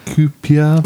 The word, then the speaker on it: Kuiper: Kuiper.